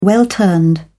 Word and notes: well-turned: British English

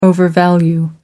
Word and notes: overvalue: American English

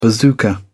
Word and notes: bazooka: British English

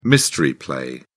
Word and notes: mystery play: British English

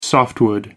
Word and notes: softwood: American English

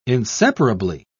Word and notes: inseparably: British English

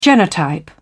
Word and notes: genotype: British English

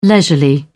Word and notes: leisurely: British English

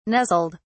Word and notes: nuzzled: British English

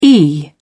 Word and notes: e.g.: British English

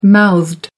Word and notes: mouthed: American English

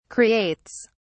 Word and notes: creates: British English

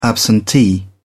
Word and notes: absentee: British English

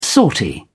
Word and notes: sortie: British English

